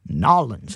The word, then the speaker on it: New Orleans: Scott Mosby at the helm as we launch and go down the Mississippi River south towards Nolens. Yeah, those of you that know New Orleans, nollins That's how it's pronounced.